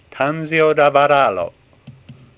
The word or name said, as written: Tanzio